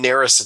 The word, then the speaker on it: Narus: My name is Ryan Narus. It's spelled N-A-R-U-S. That's N like nurse.